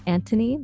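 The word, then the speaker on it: Antoni: I'm in love with two members, Karamo and Anthony, that's how you say his name.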